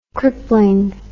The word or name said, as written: crippling